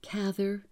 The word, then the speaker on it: Cather: Yes, I've heard all the pronunciations. I'm going to try to stick with Cather.